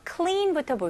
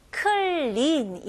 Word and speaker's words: clean: 자, 여기서는 clean 이렇게 나누시면 안 됩니다. 이거는 한국식 발음이고요. 영어로는 clean 이름절로 clean. 오케이? 그리고 이 발음을 조금 더 길게 해 주시면 더 좋고요.